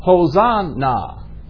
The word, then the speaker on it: Hosanna: Now when we get over into the New Testament in the Greek, this same word is pronounced Hosanna. You see, there's no Y in the Greek.